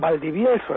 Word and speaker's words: Valdivielso: Porque se dice así, Valdivielso, ¿verdad? – Valdivielso, efectivamente.